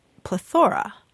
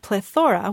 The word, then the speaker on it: plethora: But it looks like like back in 1731, there was a dictionary that did pronounce it plethora. – Really? – Yes. And the reason is that it comes from the ancient Greek, plethora, which means fullness.